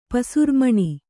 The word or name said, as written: ಪಸುರ್ಮಣಿ